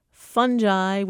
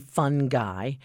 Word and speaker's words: fungi: Is it fungi with a hard G, fun guy, or with a soft G, fun G? And if it is fun G, why is fungus pronounced fungus and not fungus? So.